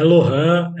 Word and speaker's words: Lorhan: É Lohan. Vamos começar já equalizando. Lohan é Lohan mesmo? – É Lohan, é Lohan. Ó, não chamando de Roland, tá tudo bem.